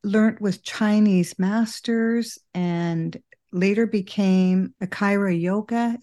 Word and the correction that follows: Kriya Yoga: Did I say that right? Kriya Yoga? Oh, Kriya Yoga. Yeah.